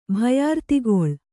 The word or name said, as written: ಭಯಾರ್ತಿಗೊಳ್